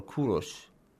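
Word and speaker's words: Kurosh (کوروش): For example, some of them mention Cyrus or Kurosh, as it's pronounced in Persian language, yeah.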